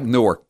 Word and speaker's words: Newark: Spell like Newark, but pronounced Newark, Delaware.